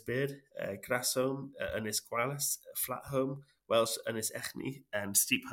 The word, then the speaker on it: Holm: And Home is spelled H-O-L-M, and it means it's an old Norse word for like island in an estuary.